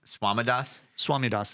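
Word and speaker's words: Swamidass: Joshua Swadamas. – Oh, yeah. – I said that wrong. Swamidas, that's him.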